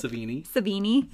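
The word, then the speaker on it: Sevigny: Savini.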